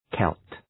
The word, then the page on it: Celt: kelt